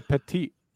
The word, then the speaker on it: petit: Petite jury. – It. Is this for me? – Yes. – Okay. I believe it's pronounced petit, but I will allow that. P-E-T-I-T space J-U-R-Y.